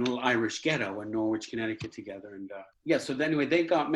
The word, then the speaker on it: Norwich: My parents they grew up together in a town called, you know, in America we say Norwich, Connecticut, but of course we all know it's pronounced Norwich, don't we? But you would say Norwich in America.